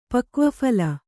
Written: ಪಕ್ವಫಲ

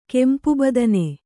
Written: ಕೆಂಪುಬದನೆ

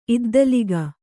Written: ಇದ್ದಲಿಗ

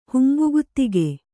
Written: ಹುಂಬಗುತಿಗೆ

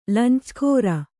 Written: ಲಂಚಗಾಱ

